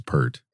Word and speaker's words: Peart: I think it's Peart. – I heard him pronounce it yesterday on a talk show when I was watching some old YouTube clips, and he said Peart.